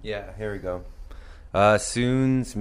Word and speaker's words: SUUNS: You guys, the name, it's pronounced SUUNS, but for those of you who don't know, it's spelled S-U-U-N-S, capitals.